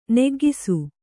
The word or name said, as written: ನಗೌಕಸ